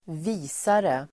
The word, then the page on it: visare: [²v'i:sare]